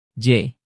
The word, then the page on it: j: Indonesian